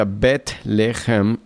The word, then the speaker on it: Bet-lehem: Y la palabra Belén es una palabra hebrea que significa, bueno, se pronuncia Bet-lehem en el hebreo y significa casa.